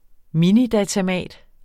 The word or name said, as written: MINIDATAMAT